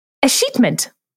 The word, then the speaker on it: escheatment: Escheatment. E-S-C-H-E-A-T-M-E-N-T. So it's like E-S-cheatment. Escheatment.